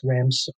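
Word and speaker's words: Ramsukh: And the last name is R-A-M as in Mary, S-U-K-H. And I know it looks weird, but it's pronounced Ramsuk.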